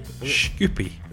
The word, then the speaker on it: Shkupi: We preview the friendly against Bury and the European tie against Macedonians, and I don't know if I'm going to say this correctly, FK Shkupi. – Shkupi.